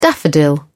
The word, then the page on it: daffodil: British English